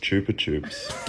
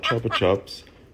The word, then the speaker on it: Chupa Chups: I just found out that in other countries, including Spain, where the brand is actually from, it's pronounced Chupa Chups. – Get out. – Chupa Chups, not Chopper Chups.